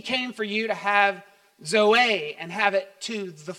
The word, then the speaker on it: zoe: The Greek word is zoe, Z O E. Some would call it, say zoe, but it's pronounced zoe.